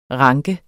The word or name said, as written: ranke